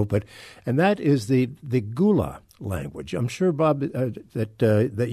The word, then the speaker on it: Gullah: Yeah, and I also know that it's pronounced Gula.